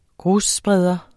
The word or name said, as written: grusspreder